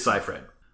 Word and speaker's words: Seyfried: Wikipedia does have a pronunciation guide. – Okay. – It is Seyfried.